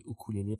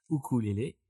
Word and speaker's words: ukulele: Alors, je dis ukulele parce que ça s'écrit U-K-U-L-E-L-E e. et pas Y-O-U comme il y en a qui l'écrivent. Voilà, donc c'est ukulele ukulélé.